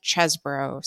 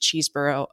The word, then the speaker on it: Chesbro: and that is one Kenneth Chesbro, sadly not pronounced Cheeseboro, according to this Washington Post reporting.